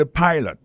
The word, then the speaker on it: pilot: Pilot là, c'est même mot pilote là, il est tiré E à derrière, écrit P-I-L-O-T. Alors, il s'est prononcé I dans cas ça. Alors, et puis, dans mot pilot là, tout, après accent tombé sur le premier syllabe là.